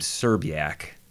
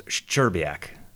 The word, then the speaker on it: Szczerbiak: and people would pronounce his last name Serbiak, but it started S-Z-C-Z. So it started with four consonants. – Yeah. – And in Polish, it would be pronounced Szczerbiak.